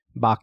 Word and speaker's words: Bach: Bach. – There you go. Bach in the back of your throat. That's how you say it.